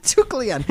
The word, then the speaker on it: Deucalion: Duke Leon. Let's ignore the A in there. It's silent.